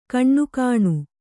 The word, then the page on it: ಕಣ್ಣುಗಾಣು: Kannada